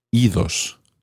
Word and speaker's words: idos: So when ir becomes reflexive, irse, meaning to go away, you actually do pronounce the D. Idos, go away. This is probably because ios is quite tricky to say. Idos sounds better.